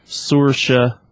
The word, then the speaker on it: Saoirse: Chalamet, and then I'm going to pronounce this properly, Saoirse Ronan. – Damn, good job.